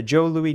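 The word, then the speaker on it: Jos Louis: The Joe Louis name has also caused a bit of a misunderstanding. The pronunciation of J-O-S as Joe, because it's spelled J-O-S, but it's called Joe Louis. The pronunciation of J-O-S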